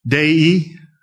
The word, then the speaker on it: Dei: The Christian life is moving towards the Visio Dei, D-E-I in Latin. Anybody know how to pronounce that? Dei? Okay, Visio Dei.